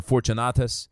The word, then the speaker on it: Fortunatus: I am delighted to have Stephanus, Fortunus, or Fortunatus, uh, and, Achaicus uh, present, because these men have easy names to pronounce.